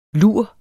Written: lur

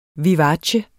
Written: vivace

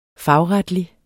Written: fagretlig

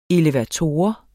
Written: elevator

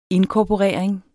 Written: inkorporering

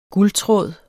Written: guldtråd

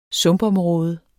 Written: sumpområde

